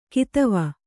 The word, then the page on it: ಕದವೆ: kadave